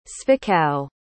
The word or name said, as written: zwickau